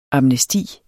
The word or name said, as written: amnesti